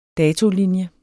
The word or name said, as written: datolinje